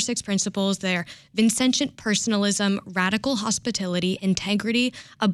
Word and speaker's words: Vincentian: Yes, so the Vincentian values that DePaul has...